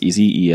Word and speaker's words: esy: Yeah, they call it ESY, but I think it's actually pronounced easy, ESY, which is their like package manager for OCaml.